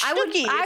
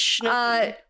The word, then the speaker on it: Schnooky: It's Schnooky. It's like a little term of endearment kind of thing. – I guess I was reading it with a short U instead of a long U.